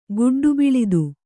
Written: ಗುಡ್ಡುಬಿಳಿದು

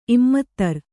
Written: ಇಮ್ಮತ್ತರು

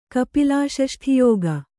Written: ಕಪಿಲಾಷಷ್ಠಿಯೋಗ

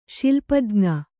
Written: ಶಿಲ್ಪಜ್ಞ

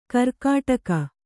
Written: ಗೀರ್ಕಡಗ

